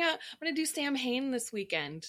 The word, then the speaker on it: Samhain: The letters M and H next to each other, I believe in Welsh or Celtic pronunciation, creates the W sound. So that's how it's pronounced, Samhain, but it's spelled Samhain.